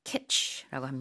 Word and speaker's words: kitsch: K-I-T-S-C-H 키치라고 합니다.